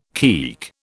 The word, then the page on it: keek: British English